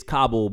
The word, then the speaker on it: Kabul: Like when you corrected me when I pronounced Kabul, Kabul, like that's fair because it's Kabul because that's how that culture pronounces that word, right?